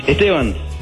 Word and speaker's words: Esteban: Esteban.